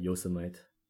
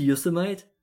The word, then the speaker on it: Yosemite: Until like 3 years ago, I always called it Yosemite. I mispronounced it.